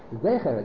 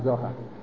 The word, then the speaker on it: Zecher: That the word is Zecher, it's not Zohar.